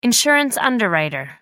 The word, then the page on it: insurance underwriter: American English